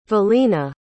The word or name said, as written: Vilina